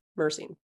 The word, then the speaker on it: myrcene: The English pronunciation is... Right. – Myrcene.